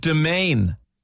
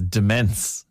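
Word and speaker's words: demesne: What about domain? Some say domain, and my other partner says demence. You know, like a domain. – Oh, or like, demesny. – Yeah, demesny. Chapel Izzard, I say it like Chapel Izzard.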